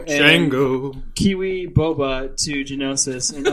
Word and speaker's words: Geonosis: let's uh, let's jump back to Geno- Geonosis. Am I saying that right? Geonosis. – That's right. Yeah.